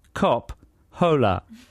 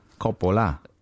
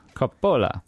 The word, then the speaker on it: Coppola: The show is called Francis Ford Cop Hola. Yeah? – Yeah, you don't pronounce the H, of course. Coppola. That's all you need. – That's true. Coppola.